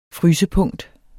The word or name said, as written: frysepunkt